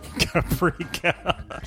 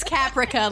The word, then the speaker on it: Caprica: It's Caprica, like Capricorn.